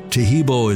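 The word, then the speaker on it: Taheebo: Tehebo is spelled T-A-H-E-E-B, like boy, O, then the word tea, and then the word club.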